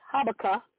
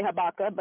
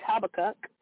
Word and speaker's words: Habakkuk: In Habakkuk, I'm saying it wrong, but it's Habakkuk. In Habakkuk, some people say Habakkuk, but it's Habakkuk, 2:2,